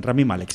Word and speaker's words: Rami Malek: En este caso creo que, no sé, que es Randy Malek, me parece que es el... no sé si lo he pronunciado mal. – Rami Malek, sí.